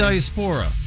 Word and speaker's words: diaspora: The diaspora? – Diaspora. – He did say it right. I will give him.